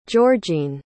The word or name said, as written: georgene